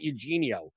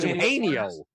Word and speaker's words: Eugenio: I think the one that may go slightly overlooked is going to be I can never pronounce his first name because it's not Eugenio. It's Eugenio.